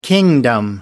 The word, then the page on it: kingdom: American English